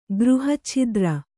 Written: ಗೃಹಚ್ಛಿದ್ರ

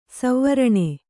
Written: ಸುವರ್ಣ

